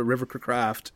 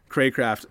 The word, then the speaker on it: Cracraft: We like had a nice scramble out of the pocket and just like lined one tight window to River Craft, Cracraft.